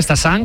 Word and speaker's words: Stassin: A pronunciarlo bien, Stassin, como nos han apuntado, y Félix Correa, con muy buena pronunciación portuguesa.